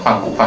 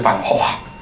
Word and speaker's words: Gogh: ？Gogh 就这样讲什么范古范古，但其实它应该是范霍华。